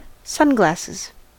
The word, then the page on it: sunglasses: American English